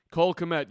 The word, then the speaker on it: Kmet: Do you think that I said that right? – Cole Kmet. Yes, I think you did.